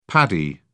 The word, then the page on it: paddy: British English